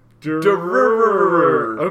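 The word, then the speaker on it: Durarara: It's abbreviated DRRR. – Oh, you mean Danganronpa? No. – Because I don't know what that is. – It's pronounced Derururur.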